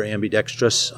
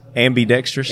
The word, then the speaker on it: ambidextrous: A lot of people add that extra I in there, and it's ambidextrous. – Ambidextrous. It's ambidextrous.